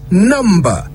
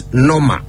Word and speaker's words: number: So remember, it's number, not noma. The B is not silent. Not when you say it right.